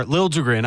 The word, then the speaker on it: Liljegren: So the Leaf pick, Timothy Liljegren, or Liljegren, I guess, is the correct pronunciation.